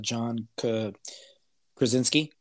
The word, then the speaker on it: John Krasinski: And then just a lot of fan service with John Krasinski.